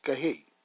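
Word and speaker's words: kahe (חי): The Hebrew word for life is chay, pronounced kahe, meaning alive.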